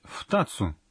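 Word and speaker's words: втацу (ふたつ): Почему здесь нужно сказать «втацу»? – Если за числительным идет суффикс «цу», произношение числительных от 1 до 10 меняется.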